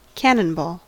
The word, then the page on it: cannonball: American English